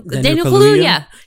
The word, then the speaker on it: Kaluuya: Anyway, this movie for me, Kiki Palmer, I can never pronounce Daniel Kaluuya.